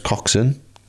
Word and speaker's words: Coxon: Yeah, I just want to give a big shout out to James Coxon. Is that how you say it? Coxon. Coxon? – Coxon. – Coxon, yeah.